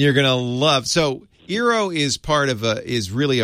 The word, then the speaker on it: Eero: I am considering getting an EERO, I guess it's pronounced Eero, Wi-Fi system.